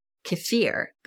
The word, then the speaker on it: kefir: You'll also hear it pronounced kefir, but I've always referred to it as kefir.